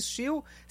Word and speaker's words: Stihl: Lembrando que Steel se escreve STIHL.